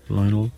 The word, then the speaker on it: Lionel: Lionel. – Is it Leon Messi? – Lionel.